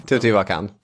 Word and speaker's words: Teotihuacan: Teotihuacan.